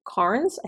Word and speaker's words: Carnes: And this term, that term trauma bonding was first coined by Patrick Carnes. I think I always say his name wrong. I think it's Carnes, C-A-R-N-E-S.